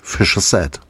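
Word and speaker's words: Fischer-Z: Ein Wortspiel mit dem Ausdruck Fischer's Head, Fischkopf, mit britischem Akzent ausgesprochen, ergibt sich klanglich Fischer Z.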